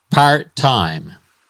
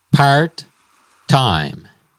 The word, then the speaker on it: part-time: Part-time. El énfasis va en la primera sílaba. Part-time.